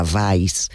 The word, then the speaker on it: Weiss: Hay que decir que es un apellido alemán, la pronunciación real sería Weiss.